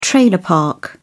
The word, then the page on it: trailer park: British English